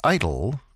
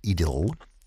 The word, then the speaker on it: idyll: An idyll, or if you prefer the English pronunciation, an idyll.